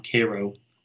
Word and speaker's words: Cairo: You know, I was in this town, Cairo. It's called Cairo, but it's spelled Cairo, but they pronounce it Cairo.